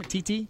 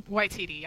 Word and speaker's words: Waititi: Do I say his name? Titi? – Waititi, yeah. – Waititi.